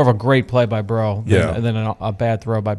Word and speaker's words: Breaux: I think it was Bro who came away with the second. You mad, bro? – Which it's so fitting that he plays for New Orleans because his name is spelled B-R-E-A-U-X.